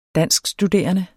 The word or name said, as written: danskstuderende